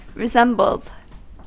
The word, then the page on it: resembled: American English